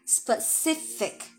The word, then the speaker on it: specific: 首先，哎，我们看这个外刊给了第一条这个 tips 啊，第一条 tip 它是什么呢？Be spe 啊，sorry，be specific about the positives 啊，这个词的发音提醒大家注意啊，千万不要发错啊，它是 specific。啊，specific，OK 啊，特别容易错，特别容易发成 specific 啊，反正容易发错啊，是 specific，OK，about the positives，就是对于比较哎积极的事情，比较好的一面的东西呢，我们可以保持 specific，非常的这个具体，哎精准，就是好的你就具体说，坏的就是可以 vague，对吧？